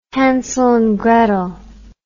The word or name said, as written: Hansel and Gretel